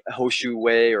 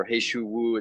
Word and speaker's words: He Shou Wu: And that means there are some amazing ingredients, some uh, powerful, powerful herbs, medicinal herbs like chaga, Romania, cordyceps, um, Hoshu Wei or He Shou Wu, it's pronounced in different ways.